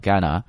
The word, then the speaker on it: Ghana: 这个国家在读的时候注意 H 这个音不发音 Ghana。